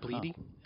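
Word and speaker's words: Blidi: B-L-I-D-I. I think that's how it's pronounced. Bleedy.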